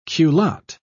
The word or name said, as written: culotte